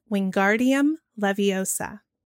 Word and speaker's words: Wingardium Leviosa: Is it the A? For both the long and the short, you'd have the Wingardium would be the long A. And in Leviosa, you'd have the short A.